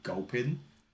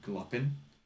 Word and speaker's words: Gulpin: Like there's a poke- Pokemon called Gulpin, and for some reason I've always called it Gluppin ever since we had the cards.